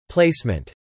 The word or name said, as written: placement